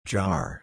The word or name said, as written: jar